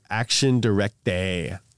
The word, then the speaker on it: Action Directe: By 1986, Renault's debt was halved, and Bessie was murdered by the radical communist group Action Directe.